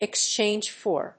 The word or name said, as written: exchange for